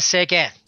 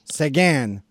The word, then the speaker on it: Seguin: Tyler Seguin? – Tyler Seguin. – Seguin.